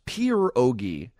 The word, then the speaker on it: pierogi: They're not pierogi. They're pronounced pierogi. – Okay. – You can, pierogi. – If you can roll the R, it helps.